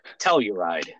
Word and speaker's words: Telluride: And the last week we were out there, I had reserved a hotel room in Telluride. And so we get to Telluride a day early. – Is that how you say that? Telluride. – Yeah.